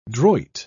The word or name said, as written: droit